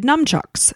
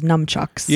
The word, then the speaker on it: nunchucks: And well, it's spelled with N-U-N. – Yeah, so just say nun. – Nunchucks. – Yeah. – I always thought it was numchucks.